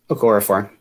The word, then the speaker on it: Okorafor: Am I saying that right? – Okorafor. Yep.